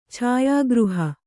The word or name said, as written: ಛಾಯಾಗೃಹ